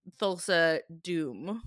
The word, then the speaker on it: Thulsa Doom: At this point, he's learned it's Thulsa Doom. Is that right? – It's the one name you pronounced correctly. – I feel like I was born to say Thulsa Doom.